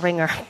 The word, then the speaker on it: Ringr: It's called you spell it R-I-N-G-R. And Ringer works... – Like Flickr. – Like Flickr, but Ringer.